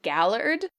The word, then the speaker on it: Gaylord: Which she insists is pronounced Gallard,